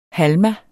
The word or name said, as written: halma